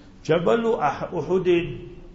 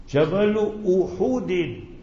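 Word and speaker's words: Jabalu Uhudin (جبل أحد): So remember, just outside Medina Munawwara, so that mountain, Habibuna sallallahu alaihi wasallam said, hadith in Bukhari Sharif, Jabalu Uhudin, pronounce the word correctly, Jabalu Uhudin bidhammil ha, pesh or the ha.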